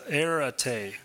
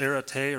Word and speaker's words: arete: And the other one is it's pronounced arete, I think is how you pronounce it, arete or something like that.